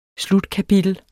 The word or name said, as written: slutkapitel